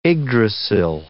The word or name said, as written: Yggdrasil